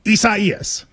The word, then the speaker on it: Isaias: A lot of people, prayers to everybody involved, but it is Isaias.